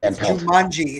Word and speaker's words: Jumanji: Jumanji. It's pronounced Jumanji.